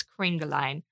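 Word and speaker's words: Kringelein: And I think it's Kriegelein.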